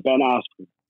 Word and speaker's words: Askren: But before we go on to that part, you know, you're fighting on the undercard of Jake Paul and I can never pronounce his name properly. Askren, is it? – Yeah, Ben Askren. – That's it.